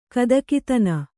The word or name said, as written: ಗದಗದನೆ